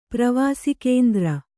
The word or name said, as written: ಪ್ರವಾಸಿಕೇಂದ್ರ